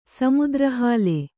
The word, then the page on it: ಸಮುದ್ರಹಾಲೆ: kannada